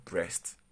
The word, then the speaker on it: Brest: Brest and Ajaccio Do not get it wrong this word is pronounced breast, but it's not your usual breast, anyways.